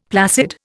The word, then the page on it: placid: Indian